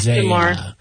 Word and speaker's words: Damar: He was DeMar... is that how you say it? D-A-M-A-R? He wore makeup. – Yeah, DeMar.